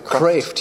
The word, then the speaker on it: Kreeft: Um, an American Catholic philosopher from Boston called Peter Kraft, or Peter Kreeft, I'm never sure quite how to pronounce it. – Kraft. He pronounces it Kraft. He's, he doesn't know how to pronounce his own name.